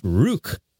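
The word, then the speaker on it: Roc: So it's actually pronounced Rook.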